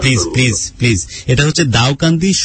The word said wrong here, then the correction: দাউদকান্দি: সোনাতলি গ্রাম, right? – না sir, এটা হচ্ছে দাউদকান্দি,